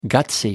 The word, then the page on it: guts: American English